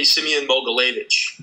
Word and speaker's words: Semyon Mogilevich: So that would be Semyon Mogilevich.